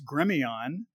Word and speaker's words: Gremillion: So it's Gremillion because it's my family is French Cajun, right? So it's one of those pesky French names where the L's are silent.